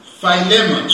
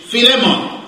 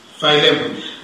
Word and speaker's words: Philemon: But I've had some help because of lemon. That helps me with the end of the pronunciation. I just need to remember lemon. I tried to get help from pronunciation from online, but it was a secular software. She got it wrong. She said in English, Philemon. I said, no, that's wrong.